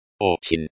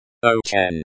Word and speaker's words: очень: Очень. Очень. The stress is on the first syllable, o. Высокое.